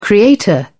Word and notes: creator: British English

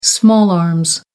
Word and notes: small arms: American English

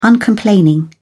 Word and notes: uncomplaining: British English

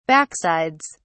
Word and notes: backsides: British English

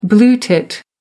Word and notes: blue tit: American English

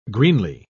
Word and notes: greenly: British English

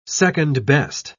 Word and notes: second-best: British English

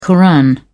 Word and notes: koran: British English

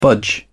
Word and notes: budge: British English

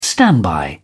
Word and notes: standby: British English

